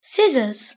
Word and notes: scissors: British English

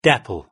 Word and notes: dapple: British English

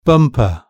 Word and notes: bumper: British English

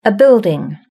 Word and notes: a building: British English

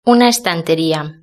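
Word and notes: una estanteria: Spanish (Spain)

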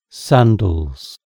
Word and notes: sandals: British English

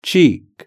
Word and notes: cheek: British English

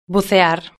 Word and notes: bucear: Spain Spanish

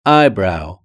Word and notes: eyebrow: British English

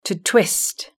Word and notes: to twist: British English

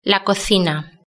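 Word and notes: la cocina: Spanish (Spain)

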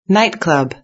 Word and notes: nightclub: British English

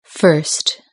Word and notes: first: British English

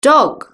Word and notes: dog: British English

